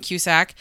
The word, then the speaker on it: Cusack: I have a hard time because we have similar last names, so I might say it wrong, but it is Joan Cusack.